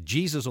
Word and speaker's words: Jesus: You know, what's it Jesus on the back of his jersey? We think Jesus is the proper pronunciation.